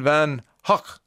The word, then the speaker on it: van Gogh: Correct pronunciation is Vincent van Hoek, like Hoek with a soft K.